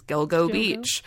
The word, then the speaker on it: Gilgo Beach: And I had to look up the meaning because I was saying it Gilgo in my head, but it sounded funny. But that's how you say it, is Gilgo Beach.